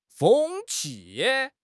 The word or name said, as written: 风气